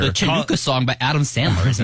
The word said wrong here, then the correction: Hanukkah: Like, how do you say it? – The Hanukkah song? – Oh, is that what it is?